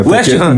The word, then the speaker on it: West Ham: É West Ham. normalmente no inglês britânico não se pronuncia.